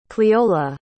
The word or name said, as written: cleola